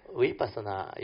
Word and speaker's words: vipassana: So vipassana is a Pali word. There are two syllables there. Vi is the three characteristics of nature. Passana is to insight into these three characteristics of nature. So you join these two words, vipassana.